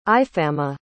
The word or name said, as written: Ifama